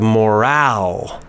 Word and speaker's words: morale: There's the word moral, and then we have morale. Again, we have the stress. The stress is different.